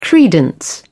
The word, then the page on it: credence: British English